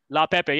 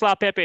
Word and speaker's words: Leaupepe: Leaupepe, yeah. Bruce Leaupepe is going to be the WWE champion in the future.